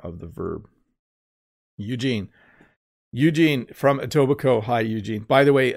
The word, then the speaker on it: Etobicoke: If you look at it, it's kind of a strange looking name and it looks hard to pronounce, but it's Etobicoke.